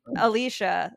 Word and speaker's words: Alicia: It's like, well, because I say my name Alicia, so that's like a more difficult, I guess. But I'll take Alicia any day.